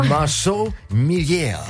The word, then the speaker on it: Marceau Millière: Also du hast uns hier mitgebracht das letzte Buch von, spricht sich französisch wahrscheinlich aus. – Genau. – Marceau Millière.